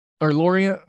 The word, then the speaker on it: Lorient: Nice, Lorenz, or Lorient, I'm sorry. I always do the wrong pronunciation on them.